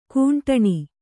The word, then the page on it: ಖಂಡನೆ: khaṇḍane